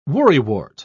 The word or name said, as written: worrywart